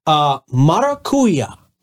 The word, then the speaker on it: Maracuya: So talk to me about this new concept, Maracuya. Did I say that right? – Yeah, you did. – Okay. – You nailed it.